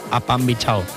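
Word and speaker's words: Apambichao: Apambinchao. La nova cançó de Manuel Turizo. Se escribe como suena, ¿eh? – Sí, sí, A-P-A-M, bichao de bicho. – Sí. – A pan bichao.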